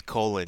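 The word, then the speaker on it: Colón: Be Colon, sir. – No, it's Colon. You said it wrong. His name is Eric Colon, the ass hat that judged it 30-27.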